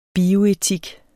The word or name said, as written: bioetik